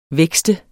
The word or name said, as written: vækste